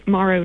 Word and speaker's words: Morrow: It's Morrow, like tomorrow.